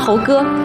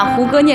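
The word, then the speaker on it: 胡歌: 他知道你喜欢猴哥，你喜欢猴哥，我胡哥啊，就经常把胡哥念成猴哥，他以为是西游记呢，是吧？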